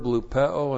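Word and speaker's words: Lupeo (λυπέω): And then the verb lupeo, and this is used also over and over in this passage. Lupeo, L-U-P-E, long O, lupeo.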